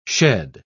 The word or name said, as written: shed